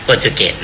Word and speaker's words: โปรตุเกส: ก็คือปี 2112 นี่คือตองอูที่ยิ่งใหญ่ที่สุดแล้วและหลังจากนั้นนะครับก็ลงมาเรื่อยๆถัดมานะฮะถัดมาก็เริ่มมีอำนาจของตะวันตกเข้ามาท้าทายประเทศแรกที่มาท้าทายอำนาจของตองอูนะครับซึ่งก็คือโปรตุเกสแต่ภาษาเราแต่ภาษาอังกฤษเขาออกโปรตุกีสโปรตุกีสแต่เราอ่านแบบเราก็เลยอ่านว่าโปรตุเกสนะครับแล้ววิธีของโปรตุเกสเข้ามาเนี่ยก็ฉลาดนะเพราะว่าคือวิธีของโปรตุเกสนะคุณอ้อมเขามาเนี่ยเขาจะไม่ยึดเอาทั้งประเทศโปรตุเกส